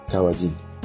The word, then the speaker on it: Cavallin: C-A-V-A-L-L-I-N, Caballín.